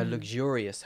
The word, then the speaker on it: luxurious: And I want to point out the difference between the pronunciation of luxury. – Mm-hmm. – It's a luxury hotel and luxurious. A luxurious hotel. The stress is different there.